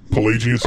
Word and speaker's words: Pelagius: Oh, that's how you pronounce that. – Pelagius. – I was reading it in the chapter, and I was like, Pelagius. – Pelagius. – Pelagius. Pelagius. Okay, that makes sense.